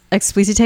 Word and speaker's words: Explicite: I think it has also the Beyonce dash on the E. – God damn it. – Yes. Explicite is such a power scent.